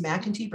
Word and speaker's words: MacKenty: McIntyre. Can I just do it for you? It's okay. Everyone gets, yeah, it's fine. And you know, it's McIntyre Brady, and it's also known as McKenty.